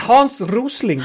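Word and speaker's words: Hans Rosling: I hope I pronounced that correctly. – It's Hans Rösling.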